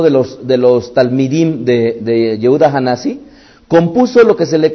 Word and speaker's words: Hanasi: Es el príncipe, o Hanasi sería más bien la pronunciación correcta, no Hanasi, sino Hanasi.